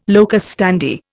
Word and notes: locus standi: Indian

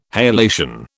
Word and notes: halation: British English

